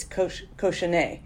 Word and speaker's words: cochonnet: Oh, okay, cochonette. – Well, if it ends in E-T, it's cochonnet.